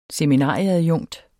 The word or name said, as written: seminarieadjunkt